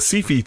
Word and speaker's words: Syfy: Anyway, so Doug reminded us all that Dark Matter's premieres happened last night on on the Sci-Fi channel. We don't call it Sci-Fi down here because it's spelled S-Y-F-Y. It's Sci-Fi. The Sci-Fi channel.